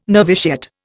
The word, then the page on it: noviciate: Indian